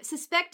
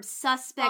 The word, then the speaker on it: suspect: No, not when it's an adjective. Suspect is a verb. Suspect Fine. – Is an adjective.